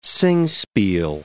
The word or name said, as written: singspiel